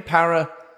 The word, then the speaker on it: paraxanthine: Paraxanthine. – Paraxanthine.